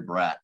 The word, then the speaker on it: Braet: So I'll just be K-State's honorary Braet or the Braet of K-State. You know, that way everyone pronounces my name right.